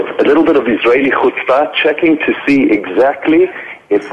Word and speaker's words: chutzpah: And I can pronounce it chutzpah.